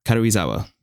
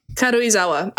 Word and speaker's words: Karuizawa: Karuizawa. Karuizawa.